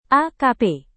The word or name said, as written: akp